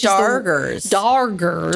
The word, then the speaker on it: Dargers: So before we go on our plagation, as they called it, with the Doggers, which Doggers. – Doggers. It's the weirdest name because you think that they're mispronouncing it, but they're not.